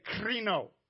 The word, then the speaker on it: krino: Say krino. Now you got to roll your R a little bit. Kreno.